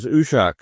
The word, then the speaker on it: Uiseag: And the band is Ushak, that's spelled U-I-S-E-A-G in Scots Gaelic, which translates into English as Skylarks.